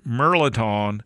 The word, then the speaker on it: Mirliton: M-I-R-L-I-T-O-N. It looks like Mirliton or Merliton.